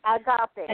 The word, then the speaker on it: Agape: Pastor, P-A-S-T-O-R, Deborah. – Okay, dot Deborah. – No, Pastor Deborah. – Pastor Deborah. Okay. Is Agape? How do you say that? Agape? – Agape.